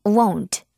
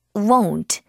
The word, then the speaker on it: won't: 요거 발음을 조금 어려워하는데 won't, won't 이렇게 하면 됩니다. 처음에 으 사운드 좀 낸 다음에 won't 입을 딱 O로 만들어서 won't, won't 이렇게 해주면 되겠어요.